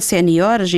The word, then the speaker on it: seniores: Quando dizemos, adaptamos ao português quando no singular pomos um acento em júnior e em sénior, mas no plural é seniores e juniores. – Juniores. – Exatamente, juniores e seniores maiores, o acento é no O, palavra, o acento não pode ir para além dessa sílaba, não é?